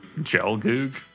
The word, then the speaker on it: Gelgoog: You said MS-1213B Gelgog, and it's actually A? – I mean, Gelgoog? – I'm going to fucking kill you.